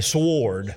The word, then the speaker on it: sword: And if you're from around here, we say sword. We pronounce the W because it's in there.